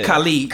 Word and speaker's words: Khalid: Not Khaled.